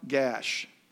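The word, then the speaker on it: Gaash: And they buried him in the land of his inheritance at Timnath-Heres in the hill country of Ephraim, north of Mount Gash. That's how they say it, Nori. That's actually how you say it.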